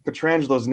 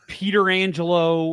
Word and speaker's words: Pietrangelo: Just I don't call him Peter Angelo or whatever Pierre McGuire calls him.